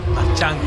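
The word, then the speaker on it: Marchán: Entonces, Gaby Rincones Jr. estará en el jardín de la derecha, y Justin Crawford en el central, y Rafael Marchand, le dijiste así, Marchand, ¿verdad? – Marchand con acento en la A.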